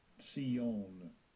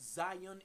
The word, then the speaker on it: Zion: Sion. Sion. – African Hebrew word Sion. We pronounce it Zion in the English.